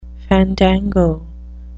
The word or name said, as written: fandango